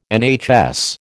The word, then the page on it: NHS: American English